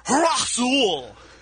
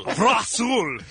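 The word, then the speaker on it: Razaul: Oh, and it's R-A-Z-A-U-L, and it's pronounced Rasul. – Rasul. – Rasul. That's how it's pronounced, yeah.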